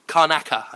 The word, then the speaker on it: Karnaca: And I'm looking forward to exploring Karnica. Is that how you pronounce it? – Karnaca, I think. – Karnaca. – Yeah.